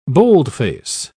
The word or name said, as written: boldface